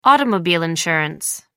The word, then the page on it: automobile insurance: American English